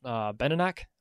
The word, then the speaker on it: Benenoch: I think how you pronounce it is Benenoch, B-E-N-E-N-O-C-H.